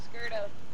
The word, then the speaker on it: Scurto: Skurto. – How do you spell that? – S-C-U-R-T-O.